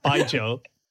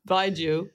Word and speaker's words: Baijiu: Baijiu. – Baijiu.